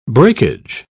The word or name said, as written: breakage